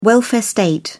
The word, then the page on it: welfare state: British English